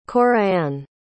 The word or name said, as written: Corianne